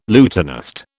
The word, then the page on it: lutanist: British English